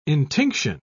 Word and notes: intinction: British English

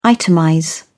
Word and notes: itemize: British English

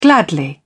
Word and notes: gladly: British English

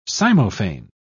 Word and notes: cymophane: British English

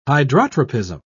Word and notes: hydrotropism: British English

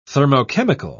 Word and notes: thermochemical: British English

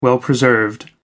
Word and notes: well preserved: American English

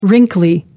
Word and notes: wrinkly: American English

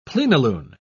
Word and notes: plenilune: British English

